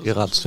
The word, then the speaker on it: Geraerts: Trotz alledem, vor jedem Spiel muss man erstmal ein bisschen schlucken und sagt dann, wow, äh, Trainer Karl Geratz, du, du, sprichst den Namen anders aus, ne? Geras? – Karel Geratz. – Geratz, ja, ja, doch.